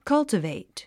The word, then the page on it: cultivate: kʌ́ltəvèit; カルタベイト